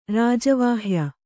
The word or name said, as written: ರಾಜವಾಹ್ಯ